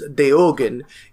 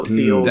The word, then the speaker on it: Deogen: So the one we pronounce as Diogen is Deogen. It is a Belgian ghost that haunts the Sonian forest that the name is Dutch for the eyes. So Deogen.